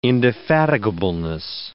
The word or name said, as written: indefatigableness